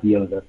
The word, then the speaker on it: Iozef: T-A-L is my first name. I-O-Z-E-F is my last name. It's pronounced Yosef. So if you need to find me on Instagram, it's The Yosef Group. So I-O-Z-E-F again.